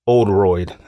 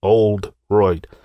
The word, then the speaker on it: Oldroyd: And those people were an individual by the name of Scott Sika, Brian Whitaker, and the other guy's name was Mark Oldroyd. I think I'm saying that right. It's old and then R-O-Y-D, Oldroyd.